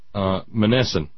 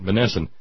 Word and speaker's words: Monessen: It was a basketball game between Pittsburgh Brentwood High School and Monessen. Pretty sure that's the correct M-O-N-E-S-S-A-N. Pretty sure that's the correct pronunciation. Monessen High School.